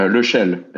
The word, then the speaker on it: Lechelle: Uh, Lechelle.